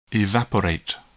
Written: evaporate

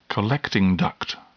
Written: collecting duct

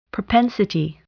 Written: propensity

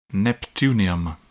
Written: neptunium

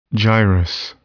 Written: gyrus

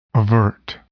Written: avert

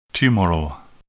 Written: tumoral

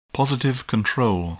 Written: positive control

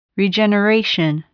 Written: regeneration

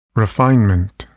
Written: refinement

